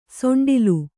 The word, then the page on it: ಸೊಂಡಿಲು: soṇḍilu